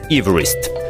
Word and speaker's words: Эверест: Еще интересно, что его фамилия произносилась немного иначе. Это было что-то вроде Эверест.